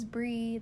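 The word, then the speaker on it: Brei: My name is Bree.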